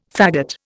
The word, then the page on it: fagot: Indian